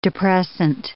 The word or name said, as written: depressant